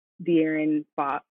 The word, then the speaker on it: De'Aaron Fox: De'Aaron Fox.